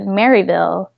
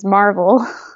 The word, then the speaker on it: Maryville: But from being southern, everybody calls it, who's not from our area, calls it Maryville, but in our area, local dialect is Marvel.